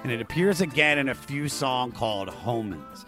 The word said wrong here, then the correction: Homens: Homen, did I say it wrong? Homen? – Yeah, Omen. – Omen. Oh, yeah. – He's saying Omen. She's saying I forgot, like, drop the H.